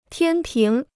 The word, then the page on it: 天平: tiān píng